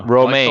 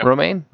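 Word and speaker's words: Romijn: Romaine. Like the lettuce. – Yeah. – Yeah. Really? Is that how you say it? – Rebecca Romaine. Yeah. – Romaine?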